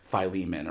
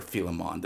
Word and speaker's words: Philemon: So one text we could look at that speaks to some of these dynamics is like the letter to Philemon or Philemon, depending on how you want to pronounce it, which is this text that was really integral to the institution of slavery in the modern West.